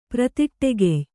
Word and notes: ಪ್ರತಿಟ್ಟೆಗೆಯ್: pratiṭṭegey

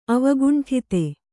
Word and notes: ಅವಕುಂಠಿತ: Kannada

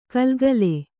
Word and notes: ಘಲ್‍ಘಲ್: ghal ghal

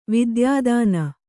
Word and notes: ವಿದ್ಯಾದಾನ: vidyādāna

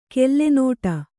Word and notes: ಕೆಲ್ಲೆನೋಟ: kellenōṭa; Kannada

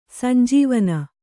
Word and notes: ಸಂಜೀವನೆ: sanjīvane

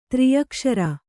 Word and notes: ತ್ರ್ಯಕ್ಷರಿ: Kannada

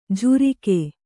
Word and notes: ಝುರಿಕೆ: jhurike

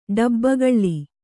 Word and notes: ಡಬ್ಬಗಳ್ಳಿ: ḍabbagaḷḷi

